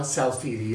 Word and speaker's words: Salfiti: It's Raja Salfidi. It's spelled R-A-J-A. And Salfiti's S-A-L-F-I-T-I.